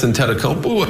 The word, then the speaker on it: Antetokounmpo: Giannis Antetokounmpo. – The NBA player Giannis Antetokounmpo caught my eye. – Bucks star Giannis Antetokounmpo.